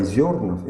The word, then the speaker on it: Зернов: Вот я принес книгу, это известный автор русского зарубежья Николай Зернов, именно с ударением на первый слог, «Русское религиозное возрождение 20 века».